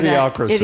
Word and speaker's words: Idiocracy: How do you say Idiocracy. – That? Idiocracy.